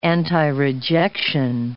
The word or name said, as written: antirejection